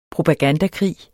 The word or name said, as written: propagandakrig